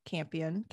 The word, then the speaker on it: Campion: So speaking of this movie, so this is directed and written by Jane Campion.